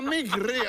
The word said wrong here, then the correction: Mike Ríos: a Mike Ríos. Mike. É que tens que pronunciar, home, tens que pronunciar.